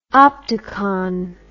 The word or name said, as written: optacon